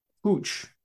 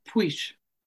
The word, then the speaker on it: Puig: A quick update about pronunciations with Catalan versus Spanish, and they think that it's more like pooch or poosh necessarily. – Is Puig.